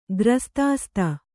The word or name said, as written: ಗ್ರಸ್ತಾಸ್ತ